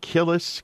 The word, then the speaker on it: Kilis: And there's a refugee camp, I believe it's at Kilis, K-I-L-I-S.